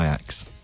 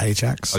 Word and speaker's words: Ajax: But he's going to go to Ajax. – It's pronounced Ajax. – Oh, sorry. He's going to go to Ajax.